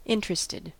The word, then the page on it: interested: American English